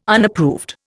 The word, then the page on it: Unapproved: Indian